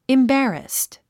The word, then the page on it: embarrassed: imbǽrəst; インバラスト